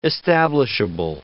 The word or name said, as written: establishable